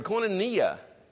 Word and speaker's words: koinonia: It's a Greek word, koinonia.